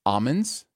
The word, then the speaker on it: almonds: I hate to break the flow of the show, but it's almonds. That you do not print, the L is silent.